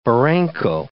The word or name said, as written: barranco